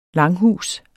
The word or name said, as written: langhus